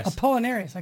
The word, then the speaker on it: Apollinaris: How do I pronounce that? – Apollinaris. – I couldn't. For a minute there, I was going to say Apollo, but yep.